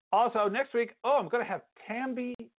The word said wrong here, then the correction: Tembi: Tembi, ah, let me get her name right. – Tembi Locke, oh, actress, and she's got a book out, a memoir, blowing up.